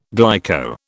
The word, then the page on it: glyco: British English